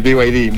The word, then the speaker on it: BYD: Sí, claro, es que verás, hay gente que le dice BYD, BYD, etcétera, pero el nombre y la pronunciación correcta es en inglés, es BY, que es Y en inglés, D, que es la D, entonces BYD.